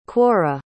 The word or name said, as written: quorra